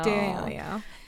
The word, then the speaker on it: D'Amelio: D'Amelio.